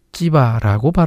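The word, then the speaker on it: 찌바라고 (צִיבָא): 시바는 히브리어로 찌바라고 발음합니다.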